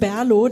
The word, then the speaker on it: BRLO: Äh, B-R-L-O. Wie wird das ausgesprochen wirklich? – Also wir persönlich sagen Berlo, das ändert sich aber auch immer mit der Länge des Abends und der Anzahl der Biere.